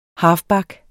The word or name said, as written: halfback